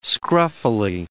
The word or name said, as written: scruffily